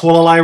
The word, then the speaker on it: Hualalai: Hualalai Realty, is that correct? – Pretty close, Hualalai Realty, yes.